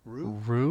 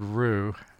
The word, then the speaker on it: Raux: Richard, and his last name is spelled R-A-U-X, and I'm going to say Rue? Rue? Like the... – A roux that you put together thicken up your stew.